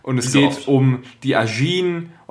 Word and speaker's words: Ajin: Ajin.